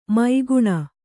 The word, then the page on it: ಮೈಗುಣ: mai guṇu